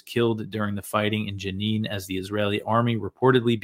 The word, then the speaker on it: Jenin: It's Jenin. I always pronounce it wrong.